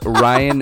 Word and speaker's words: Ryan: Mine is Ryan Durgy, R-Y-A-N. – It's your name. – D-U-R-G-Y, because I am original and creative like that.